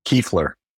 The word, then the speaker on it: Kuefler: Kiefler.